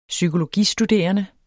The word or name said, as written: psykologistuderende